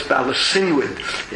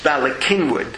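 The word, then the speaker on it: Bala Cynwyd: I lived on Conshohocken State Road, so if you can, no one pronounces Balakinwid properly. Everyone says Balasinwid. It's Balakinwid.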